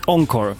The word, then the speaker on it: Encore: Encore, precis.